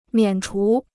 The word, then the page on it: 免除: miǎn chú